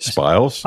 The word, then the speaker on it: spiles: I bought 3 cast iron spile. Is that how you say it? – Spiles.